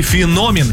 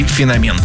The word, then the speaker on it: феномен: Ну и феномен. Именно так следует произносить это слово, хотя некоторые версии допускают иногда и феномен.